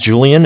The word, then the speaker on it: Julian: Okay, my name's Julian. It's J-U-L-I-A-N. – Okay. – Calling with Bank of Cardiff. Cardiff is spelled C-A-R, D as in David, okay.